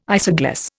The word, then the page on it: isogloss: Indian